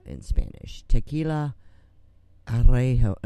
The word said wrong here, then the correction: Añejo: No, Añejo.